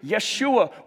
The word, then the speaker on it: Yeshua: And Jesus, or Joshua, or really pronouncing it the way they would have, Yeshua, it means God who saves, or God's salvation.